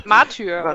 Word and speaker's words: Martyr: Und unter anderem ist da auch Warhammer 40k Matter. – Was? – Ich weiß nicht, wie es ausgesprochen wird. – Matter. – Martyr. – Oder? Martyr. – Martyr. Achso.